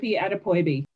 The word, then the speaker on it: Adepoyibi: Yep, my name is Tope Adepoyibi.